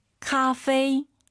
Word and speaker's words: 咖啡: 카페 아니고 카페이도 아니고 커피 다시. – 咖啡.